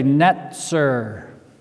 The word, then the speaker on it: netzer: And that's evident from the word that is used, that word branch, and I pronounce it in the Hebrew because you'll see the connection. The branch is a netzer and forms the basis of the name for Nazareth.